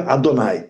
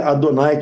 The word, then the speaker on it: Adonai: Cada vez que você vê elas escritas, você pronuncia Adonai. Você lê Yud Hei Vav Hei, pronuncia Adonai, que não tem nada a ver com essas letras.